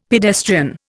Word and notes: pedestrian: Indian

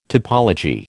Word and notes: topology: American English

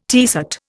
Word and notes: tiercet: Indian

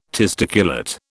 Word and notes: testiculate: British English

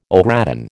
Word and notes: au gratin: American English

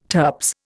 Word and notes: turps: Indian

